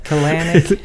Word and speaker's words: Kalanick: They're kind of, like Kalanick, is that how to pronounce his name, the Uber CEO? – Yeah, Kalanick. – It sounds like a colonic, which is like, isn't that like a yeah.